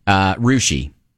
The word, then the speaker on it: Russia: There's a town in Ohio that's spelled R-U-S-S-I-A, which would be... – Russia. – Right, no. – No? – Rushi is Rushi? – How the town folk say it.